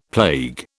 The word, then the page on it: plague: British English